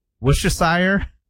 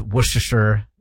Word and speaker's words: Worcestershire: So you don't say the R, you don't say Worcestershire? – No.